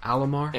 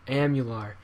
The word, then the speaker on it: Amalur: So, kingdoms of uh, how do we say this? Alamar. Amular. – Amular.